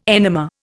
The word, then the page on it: enema: Indian